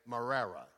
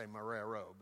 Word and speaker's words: Marrero: You actually say Marrera. You don't say Marrero, but it's spelled Marrero.